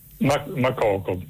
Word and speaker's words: Makoko: Mokoko con l'accento? – Makoko, sì, non so se con l'accento finale alla francese è Makoko, però probabilmente siccome quello è un paese anglofono sarà in maniera diversa.